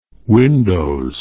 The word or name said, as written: windows